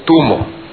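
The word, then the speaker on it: tummo: En realidad la pronunciación correcta es tumo, tibetano, el tibetano del interior.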